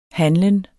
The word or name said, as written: handlen